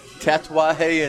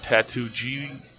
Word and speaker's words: Tatuaje: And Tatuaje in Spanish is what, fellas? – Tattoo. – Thank you. Mm-hmm. And you said it correctly. That's good. – Yeah. – Not Tattoo G.